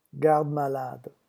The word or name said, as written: garde-malade